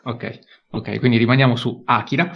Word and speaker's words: Akira: Akira o Akira, qual è la pronuncia? Jacopo, dimmelo subito così Akira. non sbaglio. – Akira. – Perfetto.